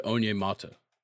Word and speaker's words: Onyemata: David Onyemata.